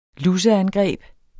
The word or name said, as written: luseangreb